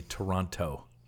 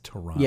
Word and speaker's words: Toronto: And then people in the States say Toronto. It's Toronto. – Yep. – No second T.